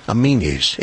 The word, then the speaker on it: Aminis: Amenies is spelled A-M-I-N-I-S. Amenies.